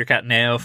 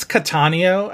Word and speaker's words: Cattaneo: I think it's Cattaneo. I think going by the way Sigourney Weaver pronounced it when she had announced.